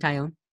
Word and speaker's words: Taillon: Can you try to pronounce Jameson Taillon? – Jameson Taillon. – A lot of people butcher that. – Does it sound like Italian or whatever? – A lot of people butcher that. I'm very impressed that you got that.